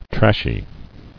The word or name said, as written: Trashy